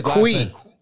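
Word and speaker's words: Cui: Victor, is it C, Sue? – It's Victor Kui. – Kui, okay, Victor Kui.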